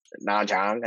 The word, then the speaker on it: Nha Trang: It's called Na Trang, and it's not how you pronounce it. Na Trang.